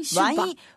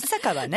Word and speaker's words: 酒場: 今日は、ワインシュバ。 – ワイン酒場かしら。まさかの、二つの読み方で、シュバ、シュって読む方を選んじゃったワイン酒場ね、ワイン酒場、うん、ワイン酒場はるばるで金曜オリジンアワーの収録が、公開収録があるそうですね。